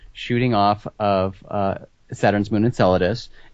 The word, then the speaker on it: Enceladus: Is that Enceladus.